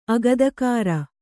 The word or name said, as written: ಅಗದಕಾರ